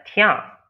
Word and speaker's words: tian: I think it's actually more of a tian. – Oh, what was that? – Maybe I'm pronouncing it wrong. A T-I-A-N. A tian? – Tian.